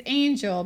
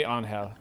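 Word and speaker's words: Ángel: It's Angel, but that's not how they pronounce Angel in Spanish. – Well, it'd be Angel.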